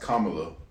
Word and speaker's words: Kamala: How do you say her name? – Kamala. – Kamala. It's actually pronounced Kamala.